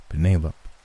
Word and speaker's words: Penelope: You're saying Pene like the pasta and lope like a dog running across the field. Penelope."